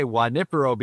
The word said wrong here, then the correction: Junipero: Beach. Michelle's is, what is the name? How do you say that name? – Well, it's Junipero. – Okay, Junipero. All right, beach in Long Beach from 8 to 10 a.m.